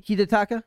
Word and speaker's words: Hidetaka: Hidetaka? Because I is pronounced like an E.